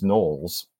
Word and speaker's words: Knowles: His name also appears in a couple of other different forms, but the reason that we can be sure that it's Knowles, not only because K-N-O-W-L-E-S is pronounced that way today,